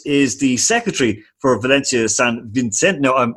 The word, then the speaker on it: San Vicente: Valencia San Vicente. – San Vicente.